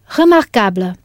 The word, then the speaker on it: remarquable: Right. Remarquable. This is spelt R-E-M-A-R-Q-U-A-B-L-E.